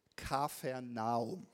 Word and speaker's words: Kapernaum: Und er kommt zu diesem Ort Kapernaum. Interessant ist, wenn man sich die Namensbedeutung von Kapernaum anschaut. Eigentlich heißt es, glaube ich, Kafernaum. So würde man das richtig aussprechen, aber ich mag lieber Kapernaum, klingt cooler.